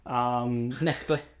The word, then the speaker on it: Knechtle: So make sure to go onto his website, Cyrill Knechtle. – Knechtle. – Knechtle. – I love it, yeah. – Am I saying it right? – Yeah, it's all right.